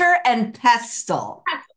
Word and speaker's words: mortar and pestle: Or you can use like a porter and mestle. I c can never pronounce the first. – Mortar and pestle.